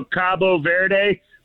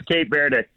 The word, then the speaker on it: Cabo Verde: How do I say that country? – Either way, you said it right. That's the it's kind of like Turkey. – Yeah, they in Spanish it's Cabo Verde, Cabo San Lucas or whatever in Mexico, Cabo Verde. But okay. The English pronunciation is Cape Verde.